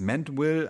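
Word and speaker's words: Mandeville: So, jetzt kommen wir zu, zu dem Autor, der, äh, der wird auch unterschiedlich ausgesprochen. Also eigentlich, äh, weil er in England schl schlussendlich tätig war, als er das geschrieben hat. Ähm, es ist Mantwill, aber er ist tatsächlich ein Holländer.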